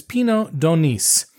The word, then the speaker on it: Pineau d'Aunis: And the grapes, as far as we know, is Pinot de Nice. Again, you pronounce the S.